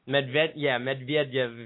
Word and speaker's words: Medvedev: Medvedev of Russia. – Medvedev, if you want to be phonetically correct.